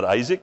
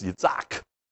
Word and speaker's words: Yitzhak: So it's not Isaac, it's Yitzhak. It's not Jerusalem, it's Yerushalayim.